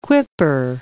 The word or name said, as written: quipper